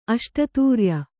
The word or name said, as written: ಅಷ್ಟತೂರ್ಯ